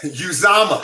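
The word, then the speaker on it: Uzomah: And Uzama, how I pronounce his last name. – Yeah. Uzama. Uzama. Yeah, so, CJ Uzama.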